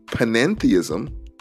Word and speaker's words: panentheism: There's theism, atheism, pantheism, panentheism, which sounds almost like pantheism, right? An extra syllable in there.